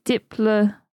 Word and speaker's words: Diplodocus: If following the Greek rules, the correct pronunciation should be Diplodocus with a soft O to rhyme with Liplodocus.